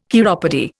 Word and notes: chiropody: Indian

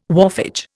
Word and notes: wharfage: Indian

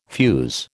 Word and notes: fuze: American English

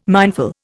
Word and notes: mindful: Indian

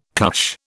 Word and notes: cush: British English

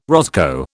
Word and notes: roscoe: British English

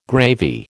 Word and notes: gravy: American English

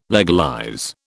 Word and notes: legalize: British English

